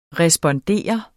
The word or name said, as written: respondere